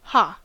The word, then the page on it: ha: American English